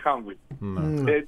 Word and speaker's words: Humvee: Είναι το ίδιο, με τον ίδιο τρόπο που έχουμε το σύγχρονο High Mobility Multi-Purpose Wheeled Vehicle, που το Humvee, που προφέρεται Humvee.